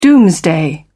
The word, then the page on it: doomsday: British English